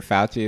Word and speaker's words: Fauci: I'm going to go out and say that since Dr. Fauci, is that how you pronounce it? – Mm-hmm. Yeah.